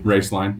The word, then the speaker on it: Roeslein: Uh, I, I think I I'm, i pronounced um, Raceland. Is that Raceland Alternative Energy? – Mm, Roeslein, yeah. – Roeslein. I, I, think I pronounced it wrong twice now.